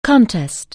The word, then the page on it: contest: anglais